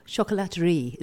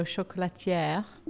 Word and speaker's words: chocolaterie: I think you should definitely take that rumour and enjoy the compliment, because it was about a chocolaterie. I can't even say the word. Chocolaterie, is that right? How do you say that word? – Yes, or chocolatière.